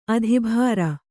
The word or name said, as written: ಅದಭ್ರ